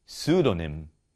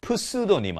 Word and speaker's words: pseudonym: 그래서 P 다음에 바로 S가 오거나 바로 N이 오면은 무음인 경우가 많습니다. 그래서 이것을 기억해서 그래서 Psy도 P가 없다고 생각하시고 발음하시면 됩니다. Psy 아니고요. Pseudonym 아니고요.